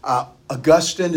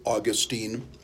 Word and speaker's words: Augustine: Augustine is how it's actually pronounced, but I learned it, Augustine.